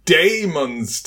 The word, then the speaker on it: Daemon's: We're talking about Daemon's Tail. – Yeah, thank you for pronouncing that with the proper A shoved in there in front of the E.